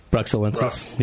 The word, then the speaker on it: Bruxellensis: Is that the way you pronounce it? – Bruxelles, I think. – Bruxellensis. Yeah. Brux. Just say Brux.